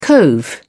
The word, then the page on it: cove: British English